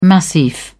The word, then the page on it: massif: British English